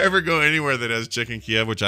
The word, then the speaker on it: Chicken Kyiv: By the way, people are pointing out that now Chicken Kiev, we have to pronounce it Chicken Kyiv.